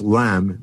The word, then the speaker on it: ylem: Well, Gamow, and working with his student Ralph Alpher, he calls the initial substance of the universe LEM, which is spelled Y-L-E-M, which is a ancient Latin term for matter.